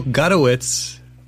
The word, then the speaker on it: Gutowitz: We're back with Jill Gutowitz. – Yes. – I said it right. – Yes, Gutowitz. Yeah. – You were telling us that because people often think it's Gutowitz. – Yeah, my nickname in high school was Gouda, like the cheese, because people thought it was Gutowitz.